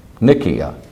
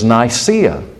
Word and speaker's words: Nicaea: And that suburb's name was Nicaea. Nicaea. Or we use it in anglicized pronunciation as Nicaea.